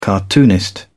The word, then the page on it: cartoonist: British English